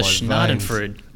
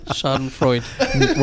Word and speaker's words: Schadenfreude: How was the Schnadenfreude? – Schnadenfreude? – Oh, my God. That's how you say it. That's how I say it anyway. – Schadenfreude.